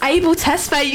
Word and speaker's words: Abel Tesfaye: First of all, we have Jamiroquai's Virtual Insanity, Arctic Monkeys, Fluorescent Adolescent, The Weeknd, Abel Tesfaye, is that how you say it? – It's Abel Tesfaye, you got it!